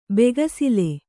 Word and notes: ಬೆಗಸಿಲೆ: begasile

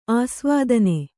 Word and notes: ಅಸಾವಧಾನ: asāvadhāna

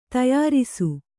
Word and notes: ತಯಾರಿಸು: tayārisu

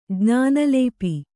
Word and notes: ಜ್ಞಾನಲೇಪಿ: kannada

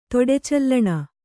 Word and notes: ತೊಡೆಚಲ್ಲಣ: kannada

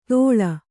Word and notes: ದೋಳ: dōḷa

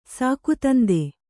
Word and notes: ಸಾಕುತಂದೆ: Kannada

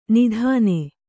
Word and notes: ನಿದಾನ: nidāna

